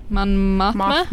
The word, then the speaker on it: Mon Mothma: Well, yeah, you have, like, in the original trilogy, you have Mon Mothma, right? Is that how you say her name? – Mon Mothma, I think.